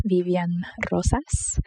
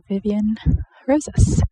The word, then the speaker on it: Vivian Rosas: I tend to pronounce my name differently whether I'm with Spanish-speaking folks or English-speaking folks. So in Spanish, my name is pronounced Vivian Rosas. Note the rolling of the R. And in English, yeah, I just stick to Vivian Rosas.